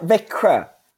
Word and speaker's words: Växjö: Växjö.